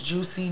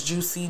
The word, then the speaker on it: JuCee: My Instagram is Juicy, J-U-C-E-E 313. My TikTok is Juicy, J-U-C-E-E 313. My Facebook is Comedian Juicy, J-U-C-E-E, that's me. – Gotta spell it out. – Spell it juicy the right way. Spell it how it sounds.